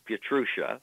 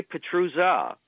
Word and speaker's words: Pietrusza: Well, the standard pronunciation in this country is Pietrusza. She would say Pietrusza,